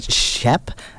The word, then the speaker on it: Stjep: I started out with Teenage Bees with Slam Hunk, and I'm still going to struggle to say it, but it's a Shep. It's spelt STJEP.